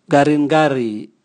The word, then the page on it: ngaringari: Raga